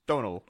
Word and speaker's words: Domhnall: Spelt Domhnall, pronounced Donal. – Okay. – Because he's Irish.